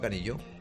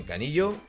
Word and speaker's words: Canillo: Yo pensaba que se pronunciaba Canillo. – No, Canillo. – Canillo, pues Canillo.